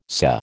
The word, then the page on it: SA: American English